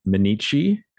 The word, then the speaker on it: Minneci: My name is Tim Minneci, rhymes with fettuccine, and I'm a podcaster, writer, musician, baker, gardener, whatever other ers you'd like to attach to my name.